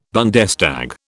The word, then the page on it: Bundestag: British English